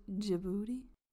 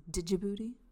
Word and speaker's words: Djibouti: Djibouti? I also like the fact that there's a silent D in the front, so it's Djibouti.